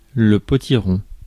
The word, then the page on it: potiron: France; /pɔ.ti.ʁɔ̃/